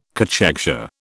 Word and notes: cachexia: British English

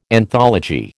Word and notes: anthology: American English

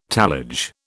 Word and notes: tallage: British English

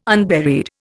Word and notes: unburied: Indian

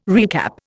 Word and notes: recap: Indian

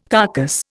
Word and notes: carcass: Indian

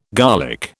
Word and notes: garlic: British English

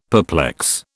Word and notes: perplex: British English

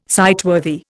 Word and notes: sightworthy: Indian

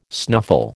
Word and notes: snuffle: American English

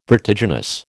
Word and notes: vertiginous: American English

